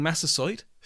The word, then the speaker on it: Massasoit: leaving Massasoit, thank you, Alan, for the correct pronunciation, and the pilgrims to themselves.